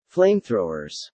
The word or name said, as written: flamethrowers